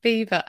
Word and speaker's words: Belvoir: It's just mental that it's spelt Belvoir and then they make us pronounce it Beaver.